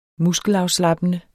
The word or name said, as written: muskelafslappende